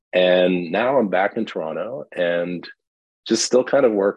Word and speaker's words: Toronto: If you're from Toronto, you don't pronounce the T in the middle. So if you hear someone say it's Toronto, they're not from Toronto.